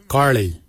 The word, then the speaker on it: gilyi: For boomerang, we say garli. Garli is the traditional name for boomerang. Garli is spelled G-I-L-Y-I, garli. For the traditional stone axe, we say gotch, gotch. Gotch is spelled G-O-O-D-J, gotch.